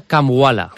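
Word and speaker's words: Kambwala: Sí, está claro que al final el Villarreal necesita ingresar, porque ya ha gastado con Willy Cambaula, si lo he dicho bien. – El nombre, tú que estuviste en la presentación, ¿es Cambaula, Cambala? – Así mismo se llama Kamwala.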